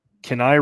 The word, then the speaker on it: Kenai: Uh, Kenai River for King Salmon.